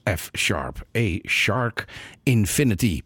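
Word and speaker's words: F♯ A♯ ∞: En dat spreek je uit als F-sharp, A-shark, infinity.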